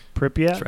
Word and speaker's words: Pripyat: He says it takes him 3 days to hike from the zone's perimeter fence to, and I'm sorry, I might butcher this, Pripyat? Is that how you say it? Pripyat? – Right.